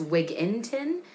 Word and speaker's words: Wigginton: There is no G in Wigginton. It's Wigginton.